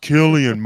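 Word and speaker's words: Cillian: It's pronounced Killian Murphy.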